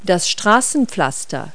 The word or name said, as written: pavement